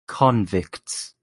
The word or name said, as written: convicts